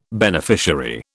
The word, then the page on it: beneficiary: British English